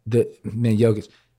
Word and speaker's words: Jokic: And that's the right way to pronounce it, Jokic. – Jokic. – Okay, get it right. Or the Joker, but it's Jokic.